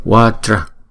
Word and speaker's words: vajra: Vajra, vajra. Chữ vajra này là hai âm. Vajra. Chữ J này chúng ta đọc là J. Vajra.